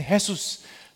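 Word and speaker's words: Jesús: 그래서 바로 이 예수스라는 단어 때문에 라틴어 또이스페니쉬또 잉글리시가 다 예수스의 발음 그대로 지저스의 문자를 따게 되는데 그래서 스페니쉬는 헤수스 라고 부르고요. 어, 어, 영어로는 지저스라고 발음을 합니다.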